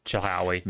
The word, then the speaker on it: Chilhowie: So for once, I think you actually pronounce it about as close as it's going to get. Yeah, that's Chilhowie.